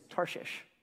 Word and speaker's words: Tarshish: That's one of those words that you're like, how do you say that one? I actually Googled it. It's Tarshish.